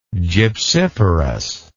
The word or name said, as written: gypsiferous